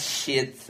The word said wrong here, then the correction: cheat: but cheat, shit. I'm European. I don't know how to pronounce it. You pronounce it. So you have a meal where you can go high. – Oh, you're trying to say cheat.